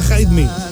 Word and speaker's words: Hide Me: Se pronuncia Hide Me, pero como todo el mundo lo va a llamar Jaime Festival, si te llamas Jaime, entras gratis, así de absurdo.